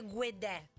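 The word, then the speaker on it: Guede: C'era quella di Storie maledette con Rudy Guedè, Rudy, che abbiamo scoperto che si dice Guedè. – Guedè, lui dice che si legge come si scrive.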